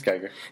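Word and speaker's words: Giger: Geiger, at least if I'm pronouncing his last name correctly. – Yeah, it's Geiger.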